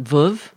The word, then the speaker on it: Veuve: Veuve? – V-E-U-V-E, Cliquot.